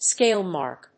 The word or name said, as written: scale mark